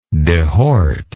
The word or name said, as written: dehort